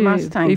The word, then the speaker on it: Mustang: Mustang, il faut prononcer group. À la française.